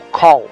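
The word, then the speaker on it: Kull: A pronúncia do nome Kul seria algo como Call, mas isso, claro, é irrelevante, já que o que vale é a forma como nós, leitores, interpretamos o que está lendo.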